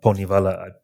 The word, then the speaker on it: Poṉṉivaḷa: So this narrative, so you're studying this legend of the of Poṉṉivaḷa.